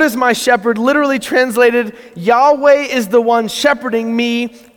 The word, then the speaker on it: Yahweh: And so our best guess is it would be pronounced Yahweh.